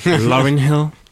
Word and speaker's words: Lauryn Hill: Mais je sais pas pourquoi, ça m'a fait penser à une vidéo que j'ai vue récente d'une interview de Lauryn Hill. – Ouais. – Je sais pas si je prononce bien son nom, mais anyway. – Je l'ai dit même trop bien. Lauryn Hill.